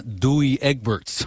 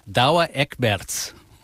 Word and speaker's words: Douwe Egberts: And as a public service for non-Dutch speakers like me, one of the Peet's coffee brands is not Douwe Egberts. I'm told it's Douwe Egberts.